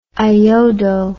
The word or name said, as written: iodo